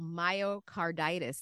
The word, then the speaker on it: myocarditis: And we were talking a little bit during the break about myocarditis.